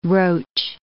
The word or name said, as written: roach